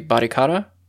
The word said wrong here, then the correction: Barichara: Barichara. – Barichara. Okay, thank you.